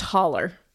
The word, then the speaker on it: hollow: Uh, we lived up in a hollow, pronounced holler.